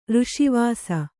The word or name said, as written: ರಸವಶ